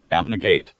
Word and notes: abnegate: American English

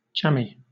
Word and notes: jammy: British English

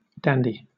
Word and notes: dandy: British English

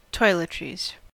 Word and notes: toiletries: American English

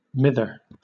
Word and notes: mither: British English

